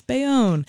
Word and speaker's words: Bayonne: I looked up the pronunciation. It's Bayonne. The